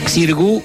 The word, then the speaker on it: Xirgu: 23 y 24 de mayo, doble show, Teatro Margarita Xirú, o Xirgu, como te guste, o como se escribe, Margarita X-I-R-U, Xirgu, Teatro Margarita Xirgu, en San Telmo, doble show de Titanes en el ring.